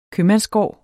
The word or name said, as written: købmandsgård